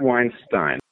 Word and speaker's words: Weinstein: It is Brett Weinstein. That's the correct pronunciation for the name, and then a lot of people have anglicized it.